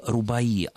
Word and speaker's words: рубаи: Действительно, слово рубаи, обозначающее специфическую форму персидской поэзии, не склоняется, и ударение падает на последний слог.